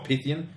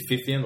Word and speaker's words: Pythian: It's not Pythian. It's actually Pythian, like phone with a PH.